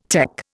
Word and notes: tech: Indian

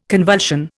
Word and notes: convulsion: Indian

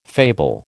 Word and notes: fable: American English

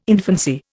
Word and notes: infancy: Indian

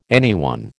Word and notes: anyone: American English

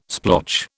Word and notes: splotch: British English

